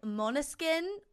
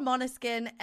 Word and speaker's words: Måneskin: And they're called Måneskin, which is, it's spelled M A with like the little circle over it. N E S K I N. But it's Måneskin is how you like say it.